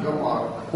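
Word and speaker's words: Йомар (יֹאמַר): Йомар. Ударение на последний слог.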